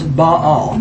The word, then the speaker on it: Baal: So think of two syllables, Baal.